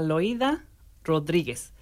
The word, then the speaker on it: Loida Rodríguez: Ustedes y yo vamos a darle la bienvenida por primera vez a Loida Rodríguez.